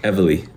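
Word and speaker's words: Evely: Sorry, I pronounced that wrong. Everly.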